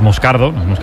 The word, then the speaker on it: Moscardo: Como bien ha pronunciado Unai, es Moscardo, es brasileño, sí, sí.